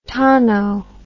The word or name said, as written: tono